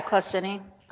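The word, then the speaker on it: claussenii: How do you say that last one? – Yeah, Clausini. – Clausini? Clausini.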